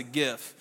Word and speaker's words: GIF: Because for the last 4 years, when I reference that, I get picked on like, duh, Pastor said GIF, ha, it's a GIF. You know, and even are those my age that you're trying to be cool, then you pick on me as well. But I've researched that. It's a soft G.